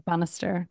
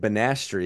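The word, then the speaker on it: Banastre: I heard somebody pronounce it as Bannister. – Yeah, I think it would be Bannister. – Tarleton. Okay, I always called it like Banastri because I didn't know how to say his fucking name.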